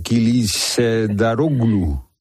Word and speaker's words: Kılıçdaroğlu: Kılıçdaroğlu. Bueno, ya vamos a ver. Que tiene una C ahí en medio con cedilla. – Sí, es como una Che. – Ah, Kılıçdaroğlu. – Ahí, perfecto. – Kılıçdaroğlu.